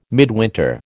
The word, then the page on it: midwinter: American English